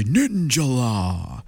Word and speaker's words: Ninjala: Okay, next up, I love the way that this is actually pronounced in the game, or Ninjala as we used to call it, but it's actually Ninjala.